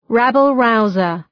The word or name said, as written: rabble-rouser